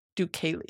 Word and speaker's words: Deucalion: So this is Deucalion. There's no L. Happy. Deucalion. Fuck!